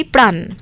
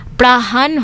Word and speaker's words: প্রাণ: ঠিক যেমন একটি উদাহরণ দিলে বুঝবেন, আমরা সহজে বলি প্রাণ। প্রাণ শব্দটি ভাওয়াইয়া গানেতে ঝোঁক দিয়ে বা জোর দিয়ে ভেঙে উচ্চারণের ফলে প্রাহান হয়ে দাঁড়ায়।